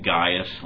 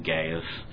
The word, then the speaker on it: Gaius: It begins with the elder, again, as he's been called in the last letter as well, unto the well-beloved, I pronounce his name Gaius. A lot of people pronounce it Gaius. My understanding is both are correct, but I'm going to call him Gaius, whom I love in the truth.